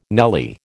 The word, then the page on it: nelly: American English